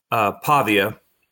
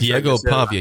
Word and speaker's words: Pavia: Just remember the quarterback for Vanderbilt, Pavia. Did I say that right? Yes, Diego Pavia.